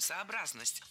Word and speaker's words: сообразность: Именно с таким ударением – «сообразность».